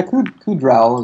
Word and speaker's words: Kudrow: Yeah, Kudrow is pretty good.